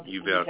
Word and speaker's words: Uvalde: the Uvalde, how you pronounce that? – Uvalde, Texas.